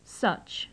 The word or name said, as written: such